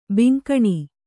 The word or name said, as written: ಬೆಂಗಾಣ್